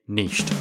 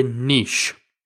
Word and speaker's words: niche: If you are from the UK, then typically that is the correct pronunciation. The word niche is taken from French, and that's the original French pronunciation of the word, niche. But if you're joining us from over in the States, or indeed I know there's several other areas in the world that pronounce it differently, you will say niche.